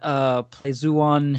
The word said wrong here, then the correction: Pleiades: It's Pleiades. – Everyone else said it wrong. Pleiades.